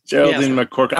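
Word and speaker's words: McCaughrean: Geraldine McCorkran.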